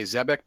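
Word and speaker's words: Xebec: And studio is Production IG, and I'm going to say Zebek because in English X's are usually pronounced Z's.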